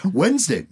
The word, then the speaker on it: Wednesday: Wednesday, you don't pronounce the D in Wednesday, and yet there is one.